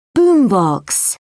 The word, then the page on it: boom box: British English